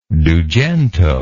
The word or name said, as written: dugento